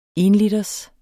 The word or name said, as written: enliters